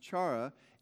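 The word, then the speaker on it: chara (χαρά): And actually, if you go to, you can pronounce it chara or chara. I believe in modern Greek it's pronounced chara.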